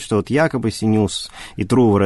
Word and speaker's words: Трувор: я не знаю, правильно ли делаю ударение? – Ну, Синеус и Трувор, наверное. – Да, да, Трувор, скорее всего.